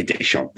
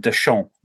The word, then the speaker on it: Deschamps: But why can't co-commentators remember that you don't pronounce the last letter of a name like Deschamps?